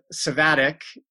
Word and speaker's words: Savadic: Today, she's presenting with Philip Savadic, the Americanized pronunciation of his name, which he endorsed.